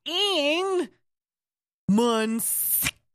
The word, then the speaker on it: Ian Munsick: Munsick. It's exactly like it sounds.